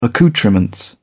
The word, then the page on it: accoutrements: British English